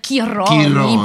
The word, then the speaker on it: Chironi: Et puis comme elle s'appelle Tonina, on va dire Chironi, puisque voilà. – Le CH en italien se prononce que, donc on va l'appeler, allez, soyons fous, Chironi.